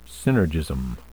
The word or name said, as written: synergism